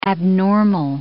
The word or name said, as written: abnormal